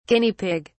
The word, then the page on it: Guinea Pig(s): British English